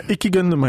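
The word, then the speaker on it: İki Gönlüm: Wir checken deinen Song Doppelherz, Ichi Gönlüm. Habe ich das richtig ausgesprochen? Äh, Iki Gönlüm heißt er, ja.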